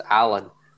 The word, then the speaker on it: Al Ain: Finishing his career at Al Ain, which is... – It's pronounced Allen.